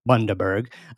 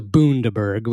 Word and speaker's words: Bundaberg: Quick correction on the name of the town. He's from Bundaberg, is pronounced und as in fun. Oh, sorry, und as in fund. Daberg. Bundaberg. As opposed to Bundaberg, which is, I think, how I'd said it.